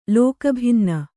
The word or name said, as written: ಲೋಕಭಿನ್ನ